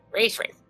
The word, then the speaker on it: Razvan: Did I say that correctly? – Yeah, Razvan. That's my name.